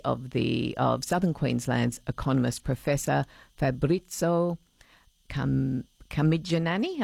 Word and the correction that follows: Carmignani: Am I saying it right? Camigianani, have I got that right, Professor? – Good evening. Yes, Carmignani. – Carmignani. There, I was not even close, but thank you for being polite about it.